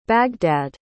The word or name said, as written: baghdad